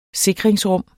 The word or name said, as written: sikringsrum